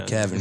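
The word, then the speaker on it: Keven: You're supposed to pronounce it Kevin, but I'm like, yo, fuck that, bro. You don't got to do all that. Just call me Kevin.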